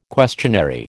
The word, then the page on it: questionary: American English